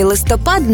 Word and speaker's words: листопад: У слові «листопад» наголос падає на останній склад у обох значеннях.